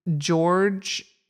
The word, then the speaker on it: Georg: The writer is George Herdeleck, although it's not spelled, it's, how do you pronounce George without the E at the end? – Georg is how I've heard it.